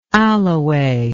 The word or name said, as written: alloway